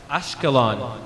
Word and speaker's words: Ashkelon: One final remark I want to make before we actually enter Gaza is this sign that says Ashkelon, which is kind of the Hebrew pronunciation of the Arabic term Asqalan, which is the Arab city of Asqalan.